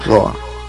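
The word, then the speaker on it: hröa: Roa. ¿Se pronuncia Roa, HD? – Sí, está bien.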